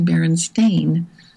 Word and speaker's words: Berenstain: you're going to notice it's spelled Berenstain, like stain, like you remove a stain with a stain remover. And so now it's not ever going to be a Berenstain or Berenstain, it's only Berenstain.